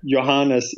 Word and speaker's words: Johannes: Johannes is basically if you would pronounce it with a Y instead of a J. – Okay. – In your way of pronouncing things, and Eckerström, good luck.